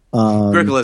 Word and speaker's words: Agricola: Yeah, Agricola.